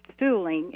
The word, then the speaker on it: Suhling: It says Vic Sulling, if I have the pronunciation correct, S-U-H-L-I-N-G. Vic's sign, Gas for Less. So that must have been prominent to people happy to find a place where they could tank up there all those years ago. How did you manage to build this sign, or was it rescued? – Okay, what the property where our museum used to house Vic Sulling, is how you pronounce it, a gas station.